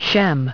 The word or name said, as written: Shem